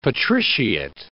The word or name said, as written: patriciate